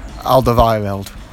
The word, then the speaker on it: Alderweireld: Oh, Alderweireld. – Yeah, him. – Oh, yeah. – Give the mic, he knows how to say it. – Alderweireld.